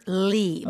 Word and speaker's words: Lea: So it's River Lee, but it's Okay. – spelled L-E-A. – L-E-A.